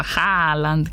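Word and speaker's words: Haaland: Εμείς το λέμε Χάλαντ και φροντίζουμε να ακουστούν και τα δύο Α. Χάλαντ.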